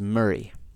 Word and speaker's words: Moray: And by the way, you're going to see it's pronounced Moray, which is how I pronounce it forever, until I learned that the pronunciation of it, even though it's spelled Moray, is Murray. So, and in Gaelic, I think it's something closer to Murrav.